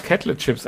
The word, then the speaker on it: Kettle Chips: Wir haben uns heute für die Kettle Chips, oder wie spricht man das aus? – Ja, richtig. – Kettle Chips entschieden. – Kettle, ja, ist richtig.